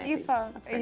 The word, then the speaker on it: Aoife: Aoife, A O I, yeah, Aoife, A O I. – Aoife.